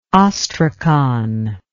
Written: ostrakon

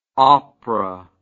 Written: oprah

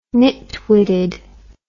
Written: nitwitted

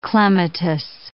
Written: clematis

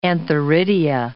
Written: antheridia

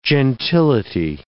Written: gentility

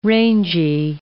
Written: rangy

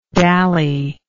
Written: dallyingly

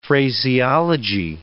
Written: phraseology

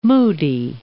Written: Port Moody

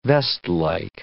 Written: vestlike

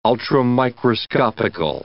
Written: ultramicroscopical

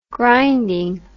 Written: grinding

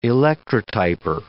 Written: electrotyper

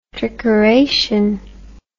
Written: trickeration

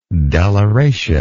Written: deliration

